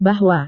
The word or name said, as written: bahwa